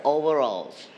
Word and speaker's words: overalls: Together, overalls. – Overalls. – All right, so you are going to stretch at the first syllable, overalls. Very good.